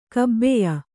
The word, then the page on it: ಕಬಾಯಿ: kannada; kabāyi